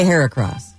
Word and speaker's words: Heracross: But it's spelled H-E-R-A cross. Like hair, like her across. – Well, but you say hair across. Or I do.